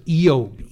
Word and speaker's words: Job: But in his book, he writes that Job, the name Job, in Hebrew is would be pronounced Eob.